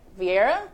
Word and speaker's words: Vieira: Vieira? – Yes.